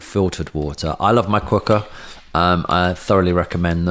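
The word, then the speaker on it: Quooker: This podcast is brought to you by Cooker, spelt Cooker, pronounced Cooker, 100 degree boiling hot water directly to your kitchen tap.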